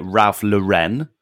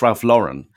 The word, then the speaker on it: Ralph Lauren: Do you know what drives me up the wall when people call it Ralph Lauren? Why is it Lauren? Who, I don't know anyone called Lauren. It's Ralph Lauren.